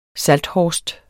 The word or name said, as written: SALTHORST